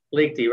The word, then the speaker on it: Liechty: And did I pronounce your last name correctly? – Pretty close. – Okay. Liechty? – Liechty, right.